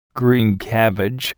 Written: green cabbage

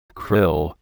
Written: krill

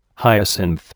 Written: hyacinth

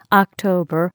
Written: October

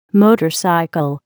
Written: motorcycle

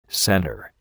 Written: center

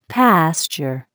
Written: pasture

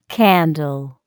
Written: candle

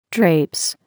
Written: drapes

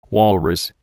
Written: walrus

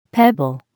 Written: pebble

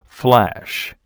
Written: flash

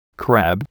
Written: crab

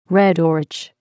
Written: red orach

